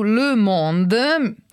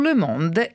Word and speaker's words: Le Monde: Grandissimo questo corrispondente, Philippe Ridé, scrive su Le Monde. Mi è stato a proposito fatto notare, si dice le e non le, come ho detto io, cadendo grossolanamente sulla pronuncia. Scrive su Le Monde e parla dell'Italia.